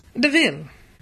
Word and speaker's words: Deville: Deville. – Could you spell that, please? – Yes, it's D-E-V-I-L-L-E.